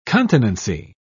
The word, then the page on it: continency: British English